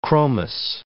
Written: chromous

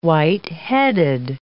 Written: white-headed

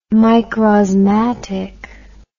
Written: microsmatic

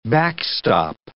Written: backstop

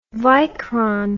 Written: Vycron

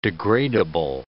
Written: degradable

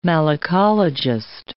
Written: malacologist

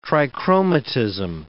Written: trichromatism

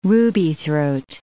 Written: rubythroat

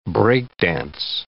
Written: break-dance

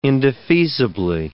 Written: indefeasibly

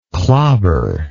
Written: clobbered

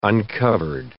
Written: uncovered

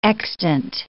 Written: extant

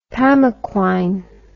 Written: pamaquine

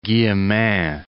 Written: Guillemin

